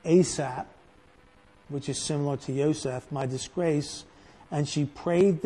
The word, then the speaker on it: Yosef: Yosef is the way it's pronounced in Hebrew.